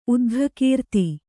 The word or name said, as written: ಉದ್ಘಕೀರ್ತಿ